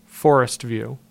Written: Forest View

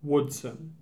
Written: Woodson

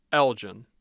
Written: Elgin

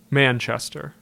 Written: Manchester

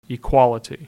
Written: Equality

